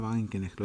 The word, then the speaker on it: Wagenknecht: Sí, para decir algo más sobre ella, eh, Wagenknecht, Sara Wagenknecht, lo digo bien, sí.